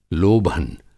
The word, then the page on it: लोभन: Hindi